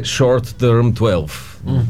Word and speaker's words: Short Term 12: Казва се Short Term 12. Добро ли ми е произношението? – Яко, яко. Ама американско е.